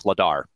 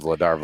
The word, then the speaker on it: Vladar: It's Ladar. The V is silent. – Is it really? – Ladar. – I thought it was Vladar.